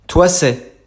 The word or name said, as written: Thoissey